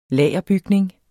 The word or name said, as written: lagerbygning